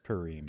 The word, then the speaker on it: Purim: Purim.